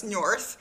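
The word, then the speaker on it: Njörðr: Okay, so then Njörth, did I say that right? – Njörth, yep, you did.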